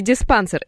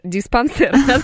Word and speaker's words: диспансер: Вот, Валерий как раз-таки пишет, что его в туп диспансер, правильно говорить, туп диспансер, не диспансер, диспансер, отправляли с манту большим.